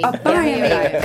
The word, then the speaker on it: Abayomi: I am your host, and I am joined by my co-hosts, Shaka Smith and Yemi Abayomi. Did I say that right? – Close. – Dang it. – Abayomi. – Abayomi. Gosh, I love the way it rolls off the tongue when I say it correctly.